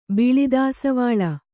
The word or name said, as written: ಬಿಳಿದಾಸವಾಳ